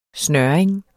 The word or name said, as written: snøring